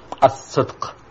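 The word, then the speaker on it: as-sidq (الصدق): Well, your pronunciation was close enough. It's as-sidq. Yes. As-sidq, which is translated more or less in English as truthfulness.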